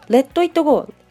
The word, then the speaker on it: Let it go: そんな Let It Go、一つ目の T、 Let It Go、 LET ITGO の一つ目の T は、次に待ち構えている I の音によって T が D と発音されます。二つ目の T は、実際発音しません。